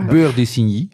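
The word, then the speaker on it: Beurre d'Isigny: Hoe spreek je dat? Insigne? – Jeroen heeft zijn mond vol. Beur de Signy.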